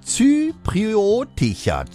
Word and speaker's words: zypriotischer: Und musikalischerseits der britisch-zyproidische, ich stolper da schon letzte Stunde drüber. Wie spricht man das aus? Zypriotischer.